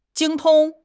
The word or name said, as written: 精通